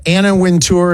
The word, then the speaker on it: Anna Wintour: I love that Anna Wintour, is that how you say it? I always thought it was Winter.